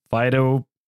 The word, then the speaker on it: Phytophthora: Phytophthora.